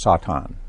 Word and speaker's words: Satan: And the Hebrew pronunciation is Satan. Yeah. The Satan.